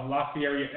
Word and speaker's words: Lafiere: Dominic Lafiere. Actually, it is kind of cooler to say it the way it's supposed to be. Lafiere. – Rhymes with Derriere.